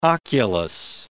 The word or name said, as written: oculus